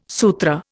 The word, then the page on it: Sutra: Indian